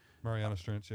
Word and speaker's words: Marianas Trench: Marianas Trench. I think I'm saying that right. – Marianas Trench, yeah.